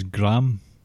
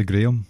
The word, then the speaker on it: Graham: His brother's name is Graham, but it's actually Graham because we say it properly.